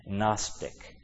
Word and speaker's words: Gnostic: And even it's even a funny word because it starts with a G but pronounced with a N. Gnostic.